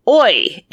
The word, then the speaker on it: oi: And oi in quotation marks. That's O-I. Mm-hmm. You could put an O-Y in quotation marks and it would be pronounced the same.